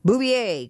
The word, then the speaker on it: Bouvier: Barbara Leeming is our guest, and we're talking about Jacqueline Bouvier. I'm sorry, I was mispronouncing that. I don't speak French. Bouvier, Kennedy Onassis, The Untold Story.